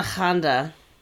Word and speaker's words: Hände: Das Mädchen ohne Hände. – Oh, right. That was the one that sounds like Honda. – Sounds like Honda Accord.